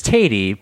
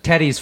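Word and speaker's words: Teti: It's not Teddy. That's not how you say it, right? – It's Tatey, but Tatey. – Teddy's fine too.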